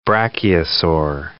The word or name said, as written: brachiosaur